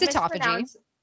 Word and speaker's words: autophagy: And so is it autophagy or autophagy? Because that is autophagy. Or I've literally always said it incorrectly. Always. But it's autophagy.